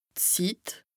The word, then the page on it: Zit: Bas Rhin